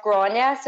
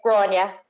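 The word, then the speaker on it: Gráinne: So it's Irish for Grace, but it's pronounced Gronya, so it's like G-R-O-N-Y-A or I-A as phonetically speaking, but it's Gronya.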